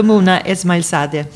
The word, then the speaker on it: Mouna Esmaeilzadeh: Välkommen Dr. Mona Esmailzadeh. Eh, var det rätt uttalat? Ja, ganska bra. Ja.